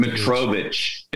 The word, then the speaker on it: Mitrovic: I don't think he's got Mitrovic. It's like M-I-T-R-I-C-O-C. – Yeah, it's the end in I-C. But you pronounce it itch.